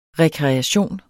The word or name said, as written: rekreation